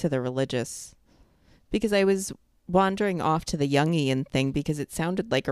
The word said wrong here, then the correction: Jungian: That was the wrong emphasis on the wrong syllable. Emphos? I said that totally wrong. – Jungian.